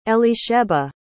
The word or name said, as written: elisheba